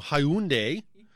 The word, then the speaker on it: Hyundai: They were calling them Hyundai. And finally, someone had to say it rhymes with Sunday, so call it Hyundai.